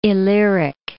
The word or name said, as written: Illyric